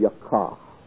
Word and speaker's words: yakah: And this word is the Hebrew word yakah. You pronounce that as though there were a double H at the end of it. If you were spelling it phonetically, it would be Yaw-Kahh, yakah.